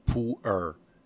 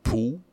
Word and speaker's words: Pu'er: It's a category of tea called Pu'er. It's a tricky category. But pronunciations are always... ¿Cómo es? Say it again. – It's two syllables. Pu'er is the first syllable, and air is the second syllable.